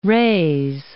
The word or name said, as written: Reyes, Point